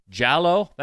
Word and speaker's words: giallo: Giallo? That's how you pronounce it? Okay. I guess it's giallo. – Okay. Which is, it's Italian for yellow, so I guess it makes sense. It kind of sounds like yellow.